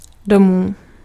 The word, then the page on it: home: English